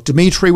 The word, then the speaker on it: Dimitri: Dimitri with three I's, D-I-M-I-T-R-I.